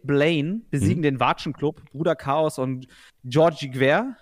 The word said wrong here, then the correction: Gwerch: Gwerch wird das gerade ausgesprochen. Georg Gwerch, würde ich sagen.